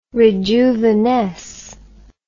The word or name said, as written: rejuvenesce